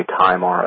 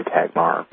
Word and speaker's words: Tajmar: And that's spelled, obviously his first name is Martin, last name is T-A-J-M-A-R. Different people pronounce it different ways. Some people say Tagmar, others say Kaggmar.